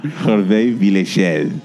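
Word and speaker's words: Hervé Villechaize: Hervé Villechel.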